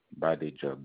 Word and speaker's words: Badejo: Badejo. B as in boy, A as in apple, D as in delta, E as in eagle, J as in joy, O as in S, Oscar. Badejo.